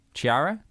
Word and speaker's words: Chiara: Yeah, but before you do, how would you pronounce C-H-I-A-R-A? Chiara? – Chiara. – Chiara? Chiara? – Chiara. I'd make it a hard K at the top.